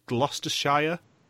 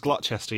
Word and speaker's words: Gloucester: When there was the cheese challenge in Quebec, and I think it was Megan who pronounced it Gloucestershire. – Mm. – Gloucestershire? – It was Gloucester, yeah.